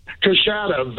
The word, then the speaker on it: Coushatta: Coushatta, Louisiana. Is that how you say it, Coushatta? – Coushatta. Very, very close, sir.